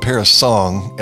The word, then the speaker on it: parasang: Well, if it's going to be called parasong, and I understand it's spelled S-A-N-G at the end, P-A-R-A-S-A-N-G, but if it's pronounced parasong